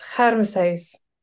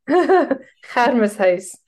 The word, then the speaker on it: Hermeshuis: It's a horrible German-derived Dutchified mess. It's Hermeshuis. – Say that again. – Hermeshuis.